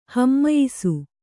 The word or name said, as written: ಹಮ್ಮಯಿಸು